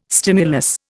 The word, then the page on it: stimulus: Indian